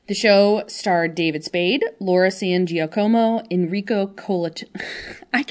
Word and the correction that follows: Colantoni: Enrico Colantoni, a.k.a.